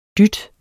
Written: dyt

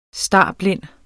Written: starblind

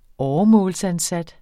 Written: åremålsansat

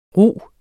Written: rug

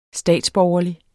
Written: statsborgerlig

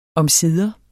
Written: omsider

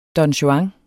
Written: don juan

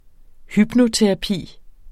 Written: hypnoterapi

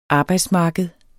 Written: arbejdsmarked

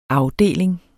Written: afdeling

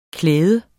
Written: klæde

